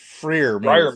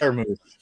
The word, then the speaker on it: Freiermuth: No, it's Pat Freiermuth, right? Is that how we say his name? – Freiermuth. – Yes. – Right, Pat Freiermuth.